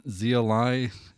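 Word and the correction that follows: Xia Li: It's X-I-A. – Oh, Zia Lee. – There you go.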